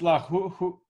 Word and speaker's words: lachuch: You're going to talk about the malach in Sfat? – Well, how do you pronounce it? It's lachuch. What is it? – Lachuch.